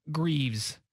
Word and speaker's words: Greaves: We're going to Marcus Greaves. It rhymes with leaves.